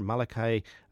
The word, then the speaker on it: Malachi: A rising star, Malachi, and I think that's how they pronounce it, actually, as opposed to Malachi that we would hear.